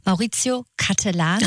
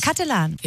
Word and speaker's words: Maurizio Cattelan: Er heißt, ich würde es wahrscheinlich falsch aussprechen, ist aber egal, Maurizio Cattelan. – Richtig. – Cattelan.